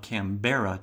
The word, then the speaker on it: Canberra: I think it looks like it's spelled Canberra to me, but my understanding is it's pronounced Canberra. And as you all probably already know, that is derived from the Aboriginal word, which means meeting place.